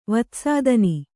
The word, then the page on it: ವಿತ್ತಸ್ಥಾನ: vitta sthāna